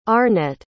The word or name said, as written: Arnet